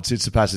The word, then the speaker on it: Tsitsipas: Tsitsipas is coming. – Wow. – Dan has said Tikasaska pass. That's how he's gone with it. Tikatek pass. Wow. – Stefanos Tsitsipas will be in town, the number 3 in the world, alongside Mario Sakari, number 6.